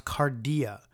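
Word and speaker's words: cardia (καρδία): In the Greek, this word is pronounced cardia,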